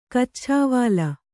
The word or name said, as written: ಕಚ್ಛಾವಾಲ